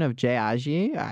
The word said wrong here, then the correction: Ajayi: Jay Ajayi.